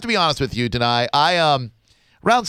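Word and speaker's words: Danai: She is played by Danae Gurira, and I hope I pronounced that right. Did I get that right, Danae? – Deny. – Of course, deny.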